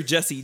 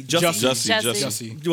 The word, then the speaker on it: Jussie: Is it Jussie or Jesse? – Jussie. – What? – Jussie. – J-U. – J-U. – Yeah, yeah, but I that's know, how it's spelled. – It's pronounced Jussie, though. – Yeah. Jussie. – No, they pronounce it Jussie.